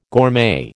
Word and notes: gourmet: American English

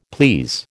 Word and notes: please: American English

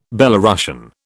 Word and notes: Belorussian: British English

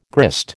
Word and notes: grist: American English

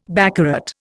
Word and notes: baccarat: Indian

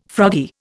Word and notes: froggy: Indian

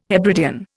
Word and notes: Hebridean: Indian

